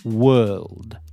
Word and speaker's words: world: So add this soft D at the end. Whirl, world, world. So there you go. Were, whirl, world. And you have world. So now let's hope that Groupies A's now likes the word world.